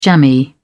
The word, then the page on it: jammy: British English